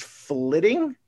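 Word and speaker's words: flyting: I want to say it is flitting. I thought it was flighting at first, but I believe they pronounced it flitting, which is the like Viking rap battle that you can do within the game.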